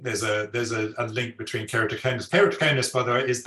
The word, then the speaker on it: keratoconus: Oh, keratoconus. – Oh. – Is there a direct link between am I saying it right? – Keratoconus.